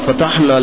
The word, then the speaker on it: فتح: جس کو ہمارے یہاں اردو میں جب بولتے ہیں تو فتح تعبیر کرتے ہیں, اصل تلفظ فتح ہے.